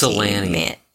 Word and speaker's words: Ypsilanti: It's Ypsilanti.